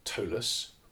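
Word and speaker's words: Ptolus: So he had his setting of Tolus, with a silent P on the front.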